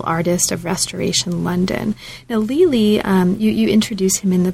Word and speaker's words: Lely: Is that how you pronounce his name? Lely? – Correct.